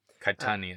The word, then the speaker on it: Caetani: Caetani, I think. I don't think the E is Caetani.